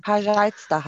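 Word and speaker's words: пожалуйста: Aber ich habe schon mal gehört, es wird anders ausgesprochen. – Pajalusta heißt das.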